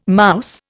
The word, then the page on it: mouse: Indian